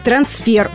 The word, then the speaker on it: трансфер: То есть правильным будет трансфер, ударное «е».